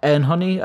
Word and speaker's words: Anohni: How do you pronounce it these days? Anhony. A-N-O-H-N-I. Anhony, I believe is how it's being said.